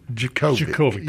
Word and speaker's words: Djokovic: Djokovic, the D is silent.